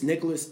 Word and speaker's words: Nicholas: Yeah, I gotta spell it out, man, because it's my name is very common, but it's Nicholas Edwards, and it's just spelled like Nickel Us Edwards.